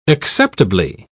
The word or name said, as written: acceptably